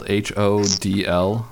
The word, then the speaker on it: HODL: It is spelled H O D L, so a lot of people may think, hey, somebody misspelled hold, and you see it all the time in the forums and on Reddit and, and in Facebook, but uh, it's spelled H O D L. Uh, you can pronounce it hodl,